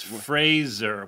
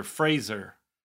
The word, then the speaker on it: Fraser: By the way, we've been saying it wrong. I'm sorry to interrupt you, but I got yelled at on the internet. It's Fraser. We've both been saying Fraser. – It's Fraser? – Fraser, like Fraser. – Like Laser. – Like Laser. Fraser.